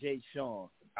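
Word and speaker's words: Jashon: I didn't get my name is Jay Sean.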